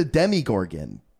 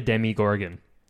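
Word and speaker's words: Demogorgon: Yeah, so you're just like a Demigorgon. – Did I get the name right, at least? – Yeah, I think emphasis on the Demi, like Demi... Like you do, like Demi Lovato or Demi Moore.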